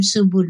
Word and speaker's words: Subud: So, Lydia, for those people who don't know about Subud, which is spelled S-U-B-U-D, Subud, tell us a little bit about it.